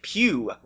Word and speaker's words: Pugh: And then you've also got this other character, Royd Pugh, rhymes with Q,